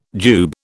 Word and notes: jube: British English

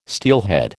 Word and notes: steelhead: American English

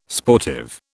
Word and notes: sportive: British English

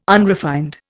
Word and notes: unrefined: Indian

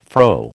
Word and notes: fro: American English

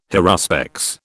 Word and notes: haruspex: British English

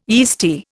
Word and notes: yeasty: Indian

